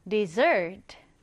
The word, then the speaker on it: dessert: 사막은 desert인데 후식이나 디저트라는 의미의 단어는요. 뒤에 강세가 들어가서 dessert. 좀 다르죠?